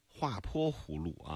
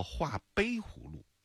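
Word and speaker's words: 画陂湖路: 前天的时候呢，就在节目过程当中呢，我们是收到蚌埠市交警支队给我们发了一则信息啊，一则封路公告，提到的是叫，我当时说叫画坡湖路啊，这个字呢是一个多音字，也念坡也念碑，后来我查了一下，这个还不能叫，不叫画坡湖路，应该叫画碑湖路。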